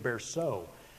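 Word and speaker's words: Bercot: The independence of each congregation made it difficult, and I'm quoting from, I guess I didn't say the author's name, David Berceau. It's spelled unusually, it's B-E-R-C-O-T.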